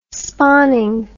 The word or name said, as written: spawning